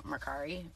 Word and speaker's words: Mercari: And then I expanded to Mercari.